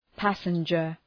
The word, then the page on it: passenger: ‘pæsəndʒər